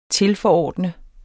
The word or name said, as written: tilforordnet